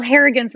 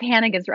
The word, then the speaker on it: Hannigan: Well, Harrigan's wrong, but Hannigan's right.